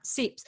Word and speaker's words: Seipps: Can you kind of start by telling us, I mean, Conrad Seipps, and again, this is spelled S-E-I, P-P-S. I think that's the German thing where you pronounce the second vowel, not Seipps. – You're exactly right. And that's something that we have been helping people remember by thinking, Seipps, it's your type. Because the Germans do pronounce E-I as I instead of E like we would in America.